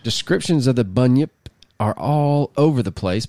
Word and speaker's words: Bunyip: and according to Bunny Yip, just the way you spell it, B-U-N-Y-I-P, Bunyip, or maybe Bunyip, not Bunny Yip.